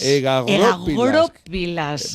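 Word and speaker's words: egagrópilas: Egagoropilas. Vamos a poner el acento en la O.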